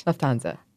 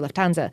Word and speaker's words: Lufthansa: Lufthansa. – Thank you, Lufthansa.